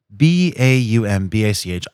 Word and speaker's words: Baumbach: Meyerowitz stories, new and selected, written and directed by Noah Bam. Here I go, Baumbach. – You just told me. – I know me. – I did. Cool, because it's spelled. Oh, so it's Noah, Baumbach. I thought it was Baumbach, which I don't know. Bombach, maybe. I've heard people say it different ways.